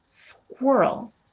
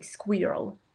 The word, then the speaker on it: squirrel: And I think part of it that I hear pronounced is like squirrel. There is no we, it's wuh. It's a much deeper sound in the mouth.